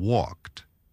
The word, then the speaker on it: walked: Note, Sam, that in the past tense, all these verbs, look, walk, talk, and watch, are pronounced with a T sound at the end. T, as in walked.